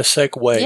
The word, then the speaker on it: segue: I believe we call that a segue. – Yes, I was giving you that segue. – And it's spelled really weird, isn't yes, it is. – S-E-G-U-E or something like that? Segue. – Segue.